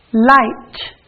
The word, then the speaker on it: light: Light, light. IGH makes the I sound, but the GH is silent. Light.